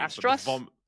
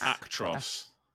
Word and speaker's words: Actros: Astros? Astro S? – Actros.